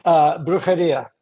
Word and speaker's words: Brujeria: Yeah, yeah, and I hope I'm saying this right, because I've never heard anybody pronounce it before. But was it Brujeria? – Yeah, Brujeria.